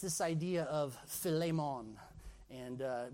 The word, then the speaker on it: Philemon: You might say, well, why are you saying it Philemon and then Philemon? Look, Philemon is really the right way to say it because the basis of Philemon's name, Philemon, is phileo, which means brotherly love.